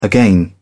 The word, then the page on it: again: British English